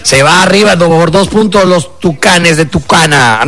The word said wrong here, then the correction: Tijuana: No, los tucanes de Tijuana. Ahí está, ya lo dije bien.